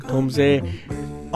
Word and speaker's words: Tom Zé: Tom Zé.